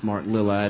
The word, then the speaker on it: Mark Lilla: So I know his name is pronounced Mark Lilla.